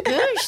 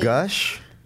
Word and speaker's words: gush: Goosh. That was what it's, which I thought was very funny. – Wait, you mean gush? – Oh, I pronounce it as goosh.